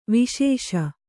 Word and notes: ವೆಸಸು: vesaasu; Kannada

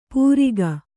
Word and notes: ಪೂರಿಗ: pūriga

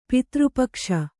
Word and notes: ಪಿತೃಪಕ್ಷ: pitř pakṣa

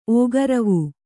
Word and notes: ಓಗರವೂ: kannada

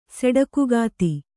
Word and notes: ಸೆಡಕುಗಾತಿ: Kannada

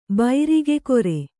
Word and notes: ಬೈರಿಗೆಕೊರೆ: Kannada

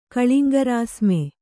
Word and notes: ಕಳಿಂಗರಾಸ್ಮೆ: kannada

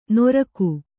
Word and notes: ನೊರಕು: noraku

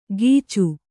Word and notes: ಗೀಚು: Kannada

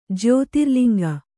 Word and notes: ಜ್ಯೋತಿರ್ಲಿಂಗ: jyōtirliŋga

